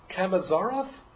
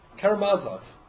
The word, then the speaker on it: Karamazov: Maybe we would, brothers, I always pronounce it improperly. Brothers Kamazarov? Karamazov.